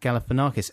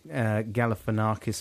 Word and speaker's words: Galifianakis: And I extremely confidently said, no, it's pronounced Galifianakis. And there was a few messages from people saying... – Saying that you were Rongo Snodgrass. – Rongoloid. It's pronounced Zach Galifianakis.